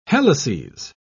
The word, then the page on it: helices: British English